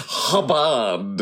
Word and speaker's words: Chabad: It's pronounced Hubbard.